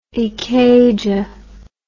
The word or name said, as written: ikeja